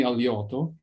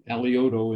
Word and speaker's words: Alioto: Well, my name is Anthony Aliotto, spelled A-L-I-O-T-O, Aliotto, and pronounced incorrectly, you might say.